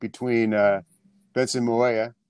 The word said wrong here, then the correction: Mayowa: Did I say that right again? I always say his name. – Benson Mayowa. – Mayowa. Not the other way.